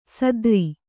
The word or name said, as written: ಸಾತಾರಿ